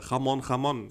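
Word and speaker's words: Jamón Jamón: Nee, es heißt äh, Jamon Jamon. – Jamon Jamon. Okay. Also es ist halt Spanisch, ne? Wird so, schön ja, mit wie, wie ein H ausgesprochen, das J.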